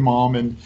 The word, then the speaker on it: Malm: So Come Back Effect, three words, and it's Jonathan Malm, and your last mm-hmm. name, I'm probably going to butcher this, Malm? – You got it. Yeah, there's a Swedish furniture collection that's called the Malm Collection.